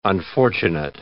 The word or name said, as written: unfortunate